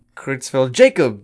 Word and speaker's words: Creutzfeldt-Jakob: I was excited until I realized you didn't just pronounce it like my name, but whatever. – Creutzfeldt-Jakob disease. – That's better, isn't it?